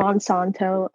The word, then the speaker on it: Monsanto: And my name is Kristina, which is spelled with a K, and Monsanto, M-O-N-S-A-N-T-O.